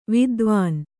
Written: ವಿದ್ವಾನು

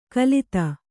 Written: ಕಲಿತ

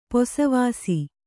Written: ಪಸೆವಾಸು

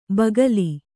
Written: ಬಗಲು

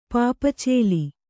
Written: ಪಾಪಚೇಲಿ

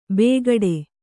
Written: ಬೇಗಡೆ

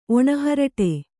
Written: ಒಣಹರಟೆ

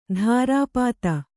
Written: ಧಾರಾಪಾತ

